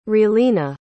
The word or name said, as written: relena